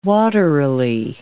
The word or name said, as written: waterily